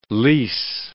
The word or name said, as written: lease